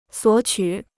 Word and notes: 索取: suǒ qǔ

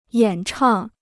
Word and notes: 演唱: yǎn chàng

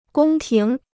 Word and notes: 宫廷: gōng tíng

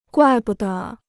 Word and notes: 怪不得: guài bu de

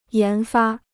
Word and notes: 研发: yán fā

